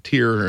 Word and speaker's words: Tyr: So I'm going to play a song from the band Tyr, or Twitter, T-Y-R, is how you spell it.